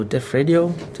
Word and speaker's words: Def Radio: Also Def Radio, es ist nicht das Todesradio, sondern... – Also nicht Death, sondern Def wie ja, Device.